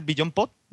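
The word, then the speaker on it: BeyondPod: Eso, yo antes tenía el Beyond Pod, ¿lo he dicho bien? – Sí, perfecto.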